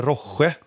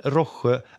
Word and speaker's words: Rohje: Eh, sedan tänkte jag bara snabbt svepa förbi ett märke som heter Roche. Tror jag det uttalas. R-O-H-J-E.